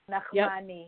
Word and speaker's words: Nachmany: Nachmany.